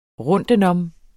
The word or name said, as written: rundtenom